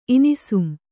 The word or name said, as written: ಇನಿಸುಂ